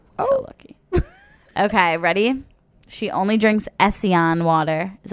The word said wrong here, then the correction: Essentia: I was, thank God you said this Essentia.